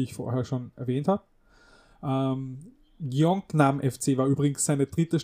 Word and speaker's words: Gyeongnam: Aber Gyeongnam wird so ziemlich hinkommen, schätze ich. – Klingt gut.